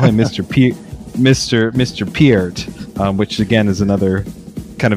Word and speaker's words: Peart: It's just Peart.